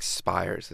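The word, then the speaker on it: Spiers: The two leads are actually people we heard earlier backing up Eliza Carthy, John Bowden and John, I think, Spires is how you say his name.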